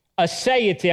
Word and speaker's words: aseity: I'd like to begin today with a theological statement on the aseity of God. Aseity, I'm going to spell it for you. It's A-S-E-I-T-Y, aseity of God.